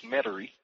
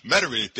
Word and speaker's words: Metairie: And with me right now is the president of that new association from Metairie. Am I pronouncing it properly? Louisiana? – Pretty close. It's Metairie. – Metairie.